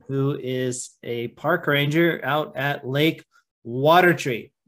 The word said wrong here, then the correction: Watery: which is Watery. – Yeah. See, I knew I was going to make that mistake again. Lake Watery. I've been saying it wrong.